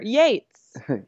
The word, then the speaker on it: Yates: Yeah, think about it like, yay, she's here. Yates.